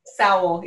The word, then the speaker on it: Sowell: But also, I will say, just with the Secretary of Commerce from Florida, Swell, did I say it right there? I was practicing. – Sowell, like how? – Sowell, yes. Secretary Sowell and others